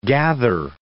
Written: gather

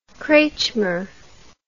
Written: kretschmer

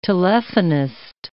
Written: telephonist

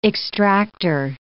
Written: extractor

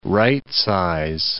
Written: rightsize

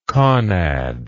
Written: conad

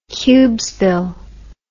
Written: cubesville

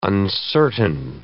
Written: uncertain

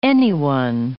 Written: anyone

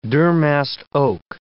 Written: durmast oak

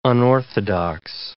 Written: unorthodox

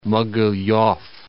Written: Mogilev

